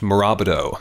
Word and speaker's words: Morabito: It is Italian. It is, I think it's pronounced Morabito. – Morabito? – In Italy. Oh, yeah. – Morabito. – I mean, we say Morabito.